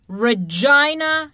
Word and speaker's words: Regina: It, of course, is the butt of many jokes, being that the city is pronounced Regina. There's a famous joke saying Regina, the city that rhymes with fun.